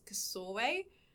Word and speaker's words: Csorwe: The main character's name is Kasorwe, but that's spelled C-S-O-R-W-E.